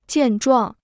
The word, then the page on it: 健壮: jiàn zhuàng